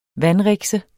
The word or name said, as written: VANDRIKSE